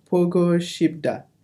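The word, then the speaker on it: Pogo Shibda (보고 싶다): But how do we pronounce it in Korean? Pogo. – Mm-hmm. – Yeah, okay. Pogo Shibda. – Very nice.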